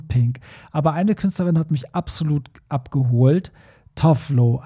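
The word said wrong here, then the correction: Tove Lo: Ah, nee, die, die, spricht man ja, was habe ich gesagt? Tuvelo spricht man die eigentlich richtig aus.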